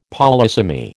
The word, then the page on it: polysemy: American English